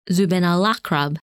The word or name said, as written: Zubenelakrab